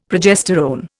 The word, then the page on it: progesterone: Indian